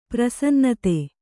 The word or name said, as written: ಪ್ರಸನ್ನತೆ